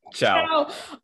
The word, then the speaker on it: Chow: I'm your host, Dion Sanchez, and joining me in this particular episode is Eric Cho. Did I say that right? – Ciao.